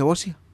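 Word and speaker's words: boccia: ¿Se dice bocia? ¿Está bien? – Bocia. – Bocia. ¿Está bien pronunciado? Bocia. Sí.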